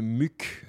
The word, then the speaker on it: Muq: Je dois avouer que c'est le petit côté Vampire Weekend qui m'a plu sur ce disque, sur ce titre de ce musicien John Muck. Ça s'écrit M-U-Q, tout simplement.